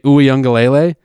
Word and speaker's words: Uiagalelei: The backup, who I finally learned how to pronounce this kid's name, DJ Uiagalelei, I just nailed it, by the way. – Very good.